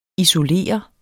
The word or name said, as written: isolere